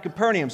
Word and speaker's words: Capernaum: You pronounce it Capernaum, right? You guys, about five of you said it. Then he went down to Capernaum.